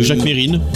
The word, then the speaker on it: Mesrine: 2 novembre 1979, c'est la mort de Jacques Mesrine. – Oui. – Qu'on appelle aussi Jacques Mérine. – Jacques oui, Mérine. – Les deux prononciations se disaient à la télévision.